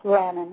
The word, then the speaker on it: Lannen: Yes, Lannen, huh? – Okay, very good.